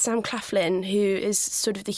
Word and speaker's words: Sam Claflin: We've got Paul Bettany, Toby Jones, again Asa Butterfield, and then we've got Sam Claflin, who well pronounced.